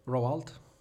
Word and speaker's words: Roald: Roald.